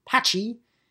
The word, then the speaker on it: Patxi: Pachi, P A T X I, is how it's spelled, but it's pronounced Pachi, and he is of Basque descent, B A S Q U E, of course, the mountainous regions of Spain.